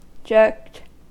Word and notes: jerked: American English

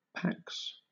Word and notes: pax: British English

